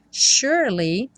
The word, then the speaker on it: surely: Surely, o S tem um som de X, sh, sh, sh, e o E do meio não é pronunciado.